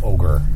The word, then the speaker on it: ogre: Ogre. – Honey, here's Yeah, it does not pronounce like it's spelled necessarily. Ogre. – It should be O-G-E-R.